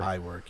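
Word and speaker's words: Iwerk: It's I-W-E-R-K, and his first name is U-B. – Yeah. – So I work or... – I work, yeah.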